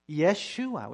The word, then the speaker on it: Yeshua: Yeshua. It's with a Y sound.